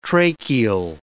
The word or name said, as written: tracheal